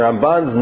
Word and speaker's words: Ramban: You'll notice that the accent is always on the second syllable. Ramban.